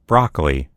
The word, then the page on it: broccoli: American English